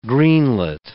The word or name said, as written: greenlet